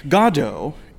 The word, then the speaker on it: Godot: You know, I've learned that Godot is the correct pronunciation. – It is Beckett's preferred pronunciation in English.